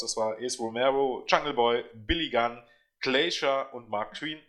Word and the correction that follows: Quinn: Äh, Mark Queen oder Mark Quinn, glaubt Mark Quinn, er ausgesprochen.